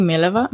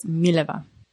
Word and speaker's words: Mileva: It's actually Mileva. But accent is on the first syllable. – Didn't I kind of say that? – No. You said it on the second, like Mileva. – Oh. – But it's Mileva.